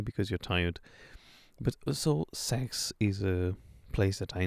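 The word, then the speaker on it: Seix: It's French, and it's pronounced sex. So exactly as you imagine, but it's not written like that. It's S-E-I-X. Sex. But you say sex.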